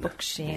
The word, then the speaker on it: Bokshan: Bokshan.